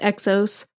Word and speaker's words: XOS: So it's pronounced Exos, but it is spelled XOS.